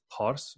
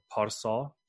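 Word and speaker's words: Pārsa: In in the older Persian, it would have been pronounced Pars or Parsa.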